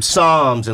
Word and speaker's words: Psalms: Psalm, how do you say it? Songs or P-S-A-A-L-M? – Psalms, and little quotations about the Jesus.